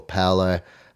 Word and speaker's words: Paulo: And Junior Paulo has been suspended, or Paolo, I believe he wants it to be pronounced now.